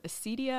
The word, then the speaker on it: acedia: So you start off in the book talking about the sin of acedia.